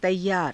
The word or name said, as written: taiyaara